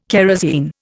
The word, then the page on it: kerosine: Indian